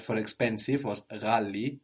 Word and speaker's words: ghali: So this GH sound is pronounced like with a little throat friction, ra, but it's not very common in Swahili. Mostly it's used for foreign words like ghali, which is Arabic.